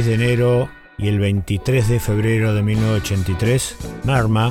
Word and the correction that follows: Murmur: o sea, Murmur, así se escribe, aparecía en las bateas de, discos en Norteamérica y de allí hacia el resto del mundo.